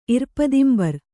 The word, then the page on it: ಇರ್ಪದಿಂಬರ್: Kannada